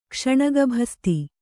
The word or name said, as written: ಕ್ಷಣಗಭಸ್ತಿ